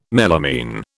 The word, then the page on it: melamine: British English